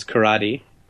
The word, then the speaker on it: karate: And a dojo is a place where you go to learn things like karate, pronounced for our American listeners as karate.